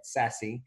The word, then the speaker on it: SASE: The acronym is SASE. Everybody's pronouncing it SASE.